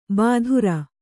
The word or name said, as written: ಬಾದುರ